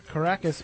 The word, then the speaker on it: Caracas: All right, and our first story comes from the LA Times, and it takes place in a place that Matt Costa tells me is pronounced Caracas, Venezuela.